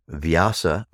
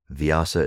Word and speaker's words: Vyasa: Now then, Vyasa, 5,000 years ago, one of the great rishi, was not known for being a seer of the Veda. He was known as Veda Vyasa. Veda Vyasa. Vyasa is spelled V-Y-A-S-A.